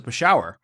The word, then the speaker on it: Peshawar: And I, you know, turn around, like, you pronounce it Peshawar. And he looks at me, he's like, well, how do you know? And I'm like, well, my family's from there.